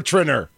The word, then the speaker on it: Treiner: It's Trinner. Yeah, so it's Czech. It's Trinner. It used to have the nice little accent over the I. – You need two N's, though. It's got to be two N's for Trinner. They spell your name wrong.